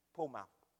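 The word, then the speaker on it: poor mouth: People, po mouth. You spell it P-O-O-R-M-O-U, po mouth. But you pronounce it po mouth, like po mouth.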